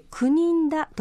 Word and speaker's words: 久米村: 今日はですね、沖縄県立博物館美術館で行われております展示会、 9 人だのお話ですよ。えー、まあ沖縄、琉球はですね、中国と大きな大きな関わりがありました。えー、久米村と書いて 9 人だと読みますけれども、